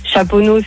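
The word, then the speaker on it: Chaponost: Chaponneau, c'est ST, tu le prononces pas.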